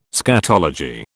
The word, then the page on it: scatology: British English